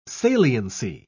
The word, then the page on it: saliency: British English